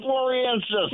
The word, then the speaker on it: floresiensis: It was the Homo floresiensis, and I can't even pronounce it. – Sciensis. Homo floresiensis.